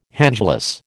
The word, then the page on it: handglass: American English